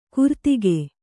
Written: ಕುರ್ತಿಗೆ

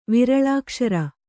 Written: ವಿರಳಾಕ್ಷರ

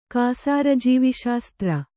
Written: ಕಾಸಾರಜೀವಿಶಾಸ್ತ್ರ

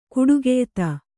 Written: ಕೊಟ್ಟಿಗೆಯೆತ್ತು

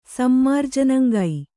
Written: ಸಮ್ಮಾರ್ಜನಂಗೈ

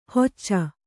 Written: ಹೊಚ್ಚ

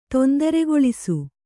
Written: ತೊಂದರೆಗೊಳಿಸು

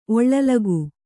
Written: ಒಳ್ಳಲಗು

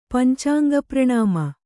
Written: ಪಂಚಾಂಗಪ್ರಣಾಮ

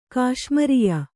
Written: ಕಾಶ್ಮರ್ಯ

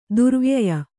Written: ದುರ್ವ್ಯಯ

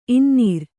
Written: ಇನ್ನೂರು

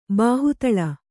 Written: ಬಾಹುತಳ

